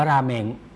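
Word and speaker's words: ราเม ง (ラーメン): ราเมง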